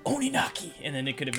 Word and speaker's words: Oninaki: Oh, I said it wrong. Oninaki. – Boom. Oninaki, that's right.